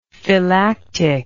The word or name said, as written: phylactic